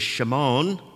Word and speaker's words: Shimon: The pronunciation in the Hebrew is Shimon, Simon of Cyrene.